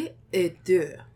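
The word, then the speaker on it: Folie à Deux: It's going to be Folie et Deux.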